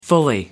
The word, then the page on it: fully: American English